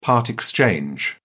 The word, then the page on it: part exchange: British English